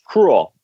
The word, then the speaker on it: Krul: It's Krul.